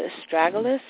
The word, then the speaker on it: astragalus: What about the astragalus? Is that how you say it? – Yeah.